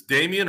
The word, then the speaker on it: Damian: No, this game was, is it Damian or Damon? What's his name? Damian or it's spelled Damian, but Damian, I hear Damon. – Damian Jones. Yeah. – Okay. Killer so game. It's Damian or Damon? – No, Damian.